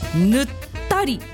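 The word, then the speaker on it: 沼垂: 新潟県の地名だと、沼が垂れる、沼が垂れるでぬったりぬったりって読むそうです、などがありますといただきました。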